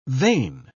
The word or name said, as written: vein